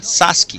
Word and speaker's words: Sasuke: É, o O vira U, né? Assim como o E vira I, né? Sasuke.